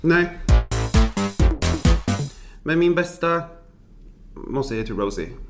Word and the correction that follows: Rosé: Ja, Rosé. – Rosé, alltså Rosé. Det här med att uttala rätt. – Tänk bara på den goda rosa drycken som man dricker.